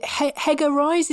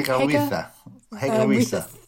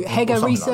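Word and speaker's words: Hege Riise: And I'm for one, really confused about the whole Team GB coaching, the new, is it Hegger Rise? Is that how you pronounce her name? – Hegger Risa. – Thank you. Hegger Risa like coming in for one of the camps, but...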